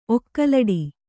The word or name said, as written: ಒಕ್ಕಲಡಿ